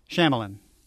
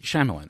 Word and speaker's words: Shyamalan: Shyamalan. – Is that how you pronounce it? – Yes. – Thank you. M. Night Shyamalan.